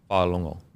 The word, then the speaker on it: Fa'alongo: I think it's Fa'alongo. – Fa'alongo. – So I don't think you say the G-go, Fa'alongo.